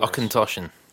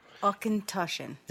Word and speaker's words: Auchentoshan: Pronounce that for Auchentoshan. – Okay. – Auchentoshan.